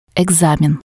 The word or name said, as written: ecsamen